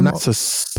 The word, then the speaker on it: Parnassus: The Imaginarium Parnassus.